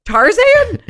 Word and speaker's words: Tarzan: And I'm like, Tarzan? He's like, no, Tarzan. – Tarzan. He puts the accent on the Zan.